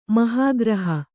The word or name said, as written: ಮಹೀಗೃಹ